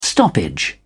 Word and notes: stoppage: British English

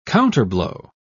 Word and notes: counterblow: British English

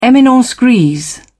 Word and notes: eminence grise: British English

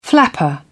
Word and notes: flapper: British English

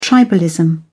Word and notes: tribalism: British English

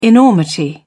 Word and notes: enormity: British English

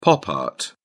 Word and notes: pop art: British English

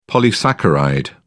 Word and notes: polysaccharide: British English

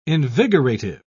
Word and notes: invigorative: British English

